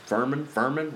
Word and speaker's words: Furman: And Furman rhymes with German.